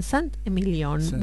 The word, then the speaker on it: Saint-Emilion: Bueno, ahora cruzamos la frontera y nos vamos a Francia y vamos a desembarcar en Saint-Emilion.